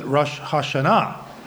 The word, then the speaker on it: Rosh Hashanah: and so they pronounce it Rosh Hashanah with an accent on the last syllable.